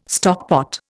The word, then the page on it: stockpot: Indian